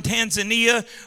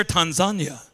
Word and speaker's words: Tanzania: Our coffee was stellar because it was coffee from Tanzania, or as they say it over there, Tanzania. Look at your neighbor and say, Tanzania.